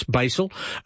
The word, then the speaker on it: Basel: What about the 8-day Christmas on the Rhine voyage from Amsterdam to Basel? Or Basel, yeah, in Switzerland. We worked on the pronunciation of that. I think it's Basel.